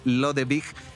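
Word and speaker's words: Lodewijk: Y sí, así es Lodewig, eh, pronunciado en el Dutch, que es distinto al Dutch alemán, hay que decirlo. Siempre ha habido mucho pique entre los alemanes y los holandeses. No vamos a entrar en esa polémica. – pero sí, la pronunciación tal cual como tú lo decías es Lodewig.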